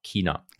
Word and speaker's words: China: Genau, richtig ausgesprochen, mit K, China.